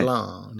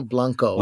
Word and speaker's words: Blanc: You don't pronounce a C. – Okay. Blanc. There's no Blanco.